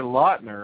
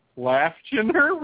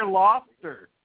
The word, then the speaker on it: Lautner: It's Taylor Lautner, right? Laughchener? Reed, go back. It's the hell's going on here? – But it's actually Taylor Lautner. And she says Laughchener.